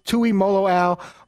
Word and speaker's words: Tuimoloau: So it's JT Tuimoloau. Molo rhymes with the chocolate-covered caramel candy Rolo. or rhymes with wow.